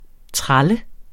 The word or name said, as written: TRALLER